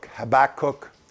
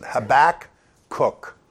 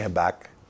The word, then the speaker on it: Habakkuk: Habakkuk is how you really pronounce it. Habakkuk. Anyway. But you can say Habakkuk. I mean, he's not going to argue with you. He's not going to correct you.